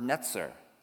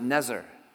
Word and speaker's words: netzer: The word branch in Hebrew is this word netzer. Netzer. And you can pronounce it Nezer.